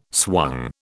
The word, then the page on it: swung: British English